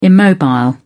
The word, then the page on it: immobile: British English